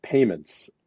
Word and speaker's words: PYMNTS: And I think there was one from a company called Payments about, which is spelled PYMNTS, which I guess is payments without vowels.